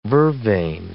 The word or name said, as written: vervain